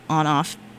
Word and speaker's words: ONF: But next on the queue, we have a song called We Must Love by ONF, On Off. I can never get this one right. I think it's On Off, but it's spelled ONF.